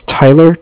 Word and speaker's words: Tyler: My name is Tyler, T-Y-L-E-R, and I am calling with Cardiff. It's spelled C-A-R-D-I-F-F, as in Frank.